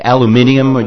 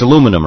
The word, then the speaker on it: aluminium: When you say aluminium, you mean like the stuff they make aluminium boats out of? It's aluminum, right? – Yep. – Okay. – In your world. – It's how they say it in New Zealand.